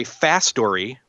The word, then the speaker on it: Fastory: So this is made by Fastory, which is spelled F A S T, like fast, and then O R Y. So it's kind of putting the words fast and story together.